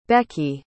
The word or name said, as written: becki